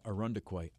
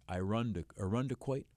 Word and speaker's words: Irondequoit: Also, notice that local pronunciation, Irondequoit. Irondequoit.